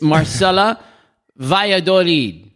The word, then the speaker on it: Marcela Valladolid: It's Marcela Valladolid.